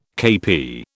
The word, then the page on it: KP: British English